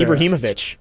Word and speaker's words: Ibrahimovic: Zlatan Ibrahimovic said that on, I believe it was on Friday night. And then Tim Howard, U.S. legend. – Great pronunciation by you. – Yeah, right, Ibrahimovic.